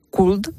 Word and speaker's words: could: Sí, pues es igual, esta es igual que walk, ¿no?, porque hay una L que no se pronuncia, entonces could. – Good. – Could. – Mm-hmm. Good. Good, lo mismo. – Sí, would, could. Entonces son L's.